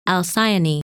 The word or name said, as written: Alcyone